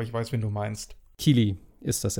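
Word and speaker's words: Keighley: Und äh, das ist auf, äh, wird auch moderiert von dem äh, Geoff Kai, Kylie, spricht man den so aus? – Ich glaube schon. Geoff, Geoff Keely? Keely, oder Keely, sowas. Keely, genau. Meine ich.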